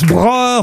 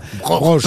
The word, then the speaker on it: Broch: Alors, je sais pas si on prononce Broer ou Broche. – Broche.